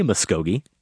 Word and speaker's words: Muskogee: There's only one way to say Muskogee, exactly how it looks. There's even a song, Merle Haggard, I believe, the Okie from Muskogee. So there's no way I would say it incorrectly. And then I got out my iPhone and I went to that part of the show and I heard myself, I think twice, say Muskogee, like Tuskegee.